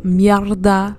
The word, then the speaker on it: mierda: Yesterday at work, I had a mierda day at work, okay? Mierd is how you say it in French. Mierda is how you say it in Spanish.